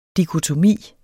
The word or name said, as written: dikotomi